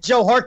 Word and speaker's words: Joe Hart: I would bring back Your Heart from loan and play him. – Yeah. – Whoa, I mean, when did Joe Hart get a soft J in his name?